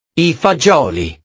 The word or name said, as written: i fagioli